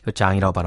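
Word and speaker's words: Jean: 소닉 매니악의 아, 소닉 매니악님이 신청해주신 REM의 Losing My Religion 그리고 지선 810님의 신청곡 트래비스의 Selfish Jin 장이라고 발음하는 거죠? Selfish 장 네.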